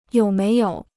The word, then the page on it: 有没有: yǒu méi yǒu